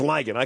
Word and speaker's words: lagan: That's ligon. Okay, so we've got Flotsam, Jetsam, and ligon. L-A-G-A-N. The ligon. – Yeah, pronounced ligon, which sounds like a werewolf, but that's okay.